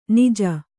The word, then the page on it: ಞ: ñ